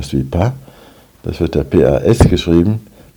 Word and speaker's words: pas: Wenn danach ein Vokal kommt, dann wird das S ausgesprochen, also je ne suis pas une. Und wenn man sagt, ich bin nicht krank, je ne suis pas Malat, da wird das S nicht ausgesprochen.